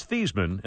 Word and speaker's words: Theismann: And by the way, his name was Theismann, and they changed it at Notre Dame to Theismann, rhymes with Heisman.